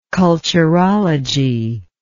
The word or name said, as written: culturological